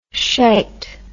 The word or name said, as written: shite